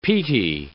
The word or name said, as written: peaty